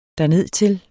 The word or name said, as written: dernedtil